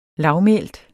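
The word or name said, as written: LAVMÆLTE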